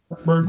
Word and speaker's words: Vaudrin: They play a ton of guys, so they're like 14th in the nation in bench minutes, and they got a guy Chandler and Vaudrin. Is that how I'm pronouncing that? – Yeah, Vaudrin. Mm-hmm.